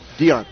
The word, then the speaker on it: Dion: The A-Team has one listed in Cindy Dion, I think is how it's pronounced. – Dion, yeah.